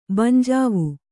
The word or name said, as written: ಬಂಜಾವು